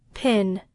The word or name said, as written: pen